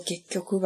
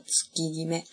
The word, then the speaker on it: 月極: 嗯，可能学过日语的人可能会按照他那个读法，就觉得应该是读作 gekyoku 吧，越极，因为极，比如说南极、北极啊。然后呢，但是其实它真正的读法读作 tsukigime，tsukigime。这个极有一个动词叫做 kiwameru，极其所尽，或者是做到极致啊，kiwameru，所以可以这样记，就就读作 tsukigime。